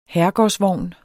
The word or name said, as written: herregårdsvogn